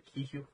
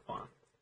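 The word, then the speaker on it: Quan: But I did hear Ki Huan. Kwan.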